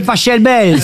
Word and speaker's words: Pachelbel: Cannelloni, c'est Pachelbel. – S'il est allemand, ça se dit Pachelbel.